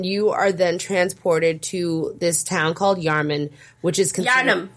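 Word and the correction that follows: Yharnam: You gotta pronounce it like it's a Massachusetts city. Yarnum. It's Yarnum.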